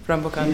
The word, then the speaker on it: Rampokan: Rambokan, do I pronounce it right? Yeah.